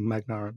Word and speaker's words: Magnar: I must say, I'm not sure if I'm pronouncing your name correctly, Magnar. Is it G silent or is it? – I would say you have a very good pronunciation.